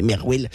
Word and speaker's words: MRWL: Et en fait, Marwal, c'est l'acronyme MRWL. Du coup, moi, au début, que je connaissais pas trop Marwal, je me dis, mais comment on prononce ça? En fait, c'est juste, on peut prononcer Merwell.